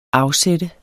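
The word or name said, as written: AFSÆTTES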